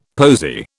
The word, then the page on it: posy: British English